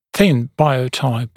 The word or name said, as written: thin biotype